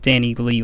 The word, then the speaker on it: Danileigh: And it's, in case you were wondering, her name is spelled D-A-N-I-L-E-I-G-H. So it's Danny Lee like that.